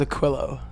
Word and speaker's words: Aquilo: That's what I've been saying Aquilo because that's how it reads to me, but how should I be saying it? – Okay, yeah, so it's normally pronounced Aquilo. Yeah, yeah. Aquilo.